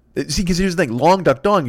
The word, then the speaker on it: Long Duck Dong: Long Duck Dong, Long Duck Dong, Long Duck Dong. But Lung Duck Dong, because I mean, oh, I said it right, I think, that time. But if you say Lung Duck Dong, I did it wrong there.